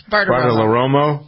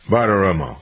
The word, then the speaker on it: Bartiromo: Bartiromo. – Huh? – No, what's take the L out. Bartiromo.